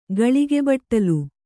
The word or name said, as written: ಘಳಿಗೆಬಟ್ಟಲು